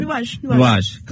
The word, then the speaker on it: Nuages: Nuages? How do I say it? – Nuages.